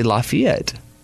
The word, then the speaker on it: Lafayette: Most people say Lafayette, like it's spelled, but it's actually Lafayette.